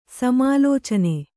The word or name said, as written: ಸಮಾಲೋಚನೆ